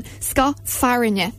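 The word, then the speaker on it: scáth fearthainne: So I found out that ska farinha is the correct pronunciation for this one. Ska farinha.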